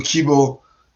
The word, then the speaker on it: Kibo: So Kibo is an American pronunciation of the word kibu, which means hope in Japanese.